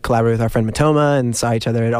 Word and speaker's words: Matoma: If you ask Matoma how to pronounce Matoma, he goes, Matoma!